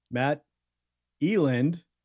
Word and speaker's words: Matt Eland: Let's welcome Matt Eland to the show. Yeah, that is the correct pronunciation.